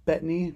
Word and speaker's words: Bettany: Because Paul Bettany, is that how you say his name? – Yeah.